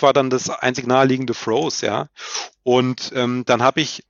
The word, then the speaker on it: Froese: Da spielt nämlich jemand, der heißt Kienz, und der Nachname wird dann geschrieben F-R-O-E-S-E. Ja, würde man deutsch aussprechen, Fröse.